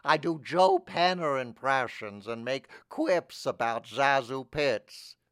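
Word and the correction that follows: ZaSu Pitts: Her name is pronounced Sazu Pitts.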